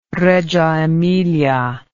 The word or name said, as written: Reggio Emilia